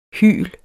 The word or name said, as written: hyl